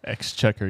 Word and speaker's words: Exchequer: That's that first record of distillation from the, I hope I'm pronouncing it right, Exchequer rolls. – Exchequer.